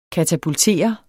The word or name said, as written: katapultere